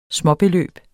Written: småbeløb

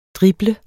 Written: DRIBLET

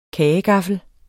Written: kagegaffel

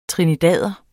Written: trinidader

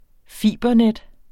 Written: fibernet

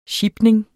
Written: sjipning

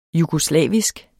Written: jugoslavisk

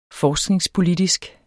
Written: forskningspolitisk

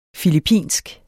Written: filippinsk

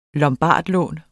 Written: lombardlån

